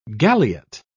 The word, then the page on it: galliot: British English